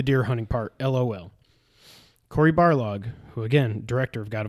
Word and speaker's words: Barlog: So, Corey Barlog, I said it right. I didn't say Balrog.